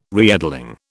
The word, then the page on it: reedling: British English